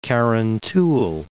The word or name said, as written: Carrantuohill